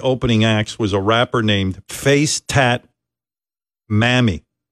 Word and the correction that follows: Mami: Mommy. – What? – Mommy, but it's spelled M-A-M-I. Mammy.